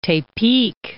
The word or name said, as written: Tepic